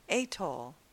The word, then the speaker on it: atoll: I've always heard and said atoll, but I see that atoll is more of the first choice for pronunciation.